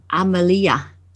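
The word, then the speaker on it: Amalija: Yes, Amalia. Yes, Amalia. It's like Amalia, but yeah, it's a Balkan spelling, so we add the J and it confuses everybody.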